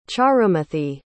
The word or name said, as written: charumathi